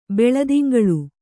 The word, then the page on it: ಬೆಳುದಿಂಗಳ್: beḷudingaḷ